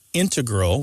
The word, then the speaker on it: integral: You mainly hear integral with the stress on the first syllable among mathematicians and specialists in scientific fields that use a lot of math.